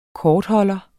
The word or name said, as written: kortholder